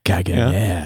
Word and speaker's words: Caganer: It's Kaganer. – Kaganer. – I hope you like my Spanish pronunciation of that R there.